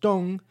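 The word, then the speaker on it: Dong (东): East is pronounced Dong, and west is Xi.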